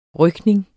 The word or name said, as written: rykning